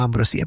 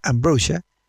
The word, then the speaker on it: Ambrosia: Este grupo que se llama Ambrosia, en castellano, sí, en español diríamos Ambrosia porque se escribe así, que en inglés se debe pronunciar Ambrosia,